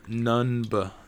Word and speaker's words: Nunb: Nian Nund? Nundb? Nunb? Numb. – Huh? Lando's, can you spell that? – Lando's... N-U-N-B.